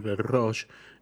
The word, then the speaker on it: Belleruche: Se llama Belroche, se escribe Belleruche, pero es Belroche.